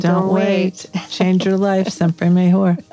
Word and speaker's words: siempre mejor: And it is siempre mejor.